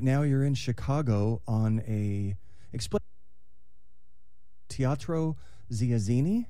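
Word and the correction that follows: Teatro ZinZanni: Did I pronounce that right? – Close, but it's Teatro Zanzani, and it is like love, chaos, comedy, and dinner, and not just that.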